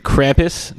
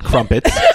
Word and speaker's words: Krampus: So we were discussing the show off the air earlier, and I will say that to Agent Nicole, it is pronounced Krampus, not Crumpets.